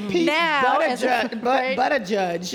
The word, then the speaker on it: Buttigieg: Well, it's Pete Buttigieg, just for people who want to know, mayor of South Bend, Indiana.